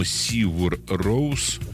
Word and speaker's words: Sigur Rós: Это группа, которая пишется Sigur Rós. Ее название произносится, по-моему, Sigur Rós.